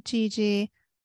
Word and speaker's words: Gigi: It's me, Gigi. I just like the way that sounds because it rhymes.